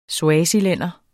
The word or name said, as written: swazilænder